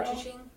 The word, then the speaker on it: gochujang: Am I pronouncing that right? Gochugaro. – Gochujang. – Gochujang. The red pepper paste.